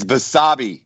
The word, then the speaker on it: Basabe: It's Basabe, like Basabe.